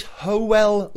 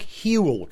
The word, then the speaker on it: Hywel: His name is Howell Lloyd. Now, it looks like Hewell because it's spelled H-Y-W-E-L. But in Welsh, the letter Y is pronounced not E. So it's Howell Lloyd, right?